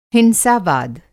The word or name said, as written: हिंसा-वाद